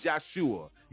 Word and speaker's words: Yahshua: There was not a letter J in the old Hebrew days. Okay, it wasn't. His name was Yahshua. Yahshua, if you want to pronounce it like that.